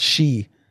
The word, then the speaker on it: sidhe: I didn't know S-I-D-H-E was pronounced she.